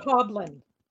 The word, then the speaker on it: Coblin: Coblin. – Coblin. – It's goblin with a C.